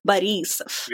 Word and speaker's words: Borisov: Another name is Borisov. So you put the emphasis on the E. So it's like Borisov.